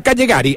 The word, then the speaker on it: Callegari: Es Stefano Callegari, el zaguero central, se escribe con ella, pero se... Bueno, Callegari, hablemoslo así. Callegari.